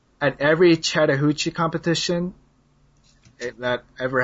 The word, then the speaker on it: Chattahoochee: Chattahoochee, yeah.